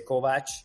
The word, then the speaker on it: Kovacs: You know, some like in Hungarian you say Kovac. Yeah. Um, and uh, you know when I try to say my name in English, I do say Kovacs.